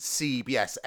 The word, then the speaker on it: Seeb: Anyway, the original acoustic version of the song was taken by a Norwegian production duo that I think is pronounced CBS, S-E-E-B.